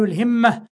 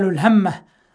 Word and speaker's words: الهمة: والهمة تنطق بكسر الهاء وفتحها، فيقال الهمة ويقال الهمة.